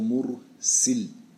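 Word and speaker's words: mursil (مُرْسِل): Der er forskel mellem mursal og mursil. Den første der med fath sin, stregen er over bogstaven. Den anden med kasr sin, stregen er under bogstaven.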